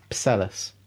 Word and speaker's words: Psellus: E-T-E. – Yeah, that's right to me. – No, it's spelled P-S-E-L-L-U-S. – Pselus. – Pselus. – Pselus. – Pselus. – Pselus. – Yeah, Pselus. – Pselus, yeah. – Pselus will do because the P-S sound is hard.